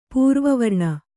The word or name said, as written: ಪೂರ್ವವರ್ಣ